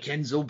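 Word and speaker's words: nZo: So Enzo Amore's new ring name would be Enzo, and it was spelled lowercase n, capital Z, lowercase O. Get it? – Right, okay. – Get it? Because it sounds like Enzo, but it's spelled differently.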